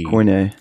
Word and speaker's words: Koine: And I don't know how to say the word. It's K-O-I-N-E. – Koine.